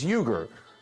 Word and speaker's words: Uygur: Second name, surname is Uygur. U-Y-G-U-R. Like you, girl. So, don't let it throw you. It's not Yank, Hank, or Plank. Okay, it's Cenk, like Jenkins, Uygur, like Uygur.